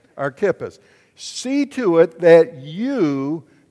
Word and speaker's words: Archippus: Okay, but his name's Archippus, and that's how we pronounce it in English. But if you really want to pronounce it the way we should in English, it would be Archippus. Look at somebody say Archippus. So you see, we look at the CH there, and we think Chip, it's Kip.